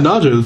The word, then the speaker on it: Naja: Formerly Naja. Is that the proper pronunciation? – Yeah, Naja. It's Cobra.